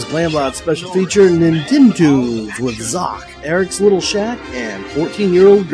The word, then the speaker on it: Xoc: And last up, we have The Goonies 2 and In Theme Song, covered by Zoc. That's spelled X-O-C but pronounced Zoc.